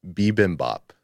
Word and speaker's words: bibimbap: What was it though? I can't remember. – Bibimbap. – Bibimbap. And I said bibimbap or something. Boombap. Boom, boom. – Yeah. I said boombap.